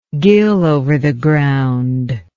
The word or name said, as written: gill over the ground